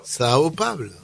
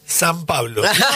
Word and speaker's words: São Paulo: ¿Podés repetirlo? – São Paulo. – A ver, ¿puedo probar? – Dale. – San Pablo. – No. – Es que así se dice.